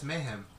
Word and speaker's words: mayhem: Does that sound wrong? Mayhem. – Yeah, it's mayhem. – It's mayhem.